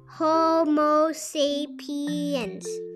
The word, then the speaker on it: Homo sapiens: You say it like this Homo sapiens.